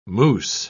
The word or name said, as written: moose